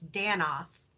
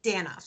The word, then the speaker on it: Danoff: It's Danoff.